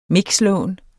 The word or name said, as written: mixlån